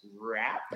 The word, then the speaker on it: Rapp: And, hey, what better way to replace him than coming back with a safety out of the University of Washington, Taylor, I believe his last name is pronounced Rapp. – Yes, sir. – I believe that's how he said.